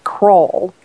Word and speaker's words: Crowell: It's, he says Kroll, like K-R-A-L-L almost, but it's spelled Crowell. So it's Grant, C-R-O-W-E-L-L, at Grant Kroll.